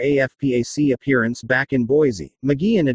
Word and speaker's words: McGeehan: Among the five Republican officials who spoke was McGeehan, pronounced Ma-Gee-Hin, Idaho's lieutenant governor.